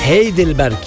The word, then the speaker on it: Heidelberg: Heidelberg değil, Heidelberg. Heidi'nin kasabası mı orası? Heidelberg. Heidelberg değil, yanlış söylemişim. Yanlış telaffuz etmişim. Heidelberg. Yani Heidelberg olarak okunuyor.